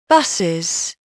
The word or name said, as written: buses